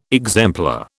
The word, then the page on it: exemplar: British English